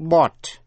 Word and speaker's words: bought: Số 5, sau đây là cách phát âm của những âm tiết O-U-G-H. Có nhiều cách phát âm. Phát âm là O trong chữ bought. B-O-U-G-H-T, bought.